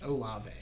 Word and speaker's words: Olave: Is that how you say his last name? – Yeah. – Just Olave. – Yeah. – Okay. – You got it right.